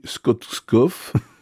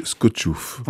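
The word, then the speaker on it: Skoczów: Ça s'écrit Skotskov, mais il faut prononcer Skotchouf.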